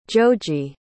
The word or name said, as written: joji